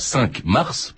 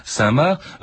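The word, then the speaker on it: Cinq-Mars: Je précise aussi que on dit parfois 5 Mars, parce que ça se prononce comme ça, c'est INQ comme le chiffre et Mars comme la planète, mais ça se prononce Saint-Marc, Françoise Éril de Scheimer.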